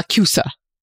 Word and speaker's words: MACUSA: The M-A-C-U-S-A, the U.S. magical thing. – Mm, yeah. – The... – Magical Congress of the United States. – Magical Congress. – Yeah. Macusa.